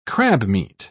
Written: crabmeat

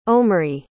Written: omri

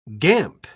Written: gamp

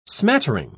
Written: smattering

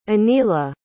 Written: aniela

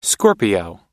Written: scorpio

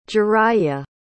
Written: jiraiya

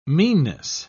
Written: meanness

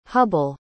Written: hubble

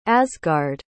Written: asgard

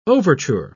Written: overture

